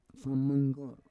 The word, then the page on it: fomonggor: Roon